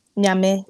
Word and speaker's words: Nyame: Nyame. Nyame. So spell N-Y-A-M-E.